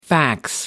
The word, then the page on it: fax: American English